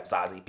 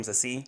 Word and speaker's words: Zazie: Simmons, is a person of color, because you have Zazie Beetz that plays the main love interest. Oh, that's how you say her name, Zazie? – Yeah, it's Zazie. Like French.